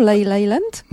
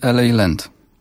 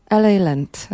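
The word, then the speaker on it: La La Land: No to będzie piosenka z filmu, ale jak dzisiaj wymawiamy w porannej audycji tytuł tego filmu? Leyland? – Nie, LA Land. – LA Land.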